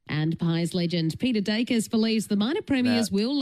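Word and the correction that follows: Daicos: Amy, it's not Dacus. It's Dacos.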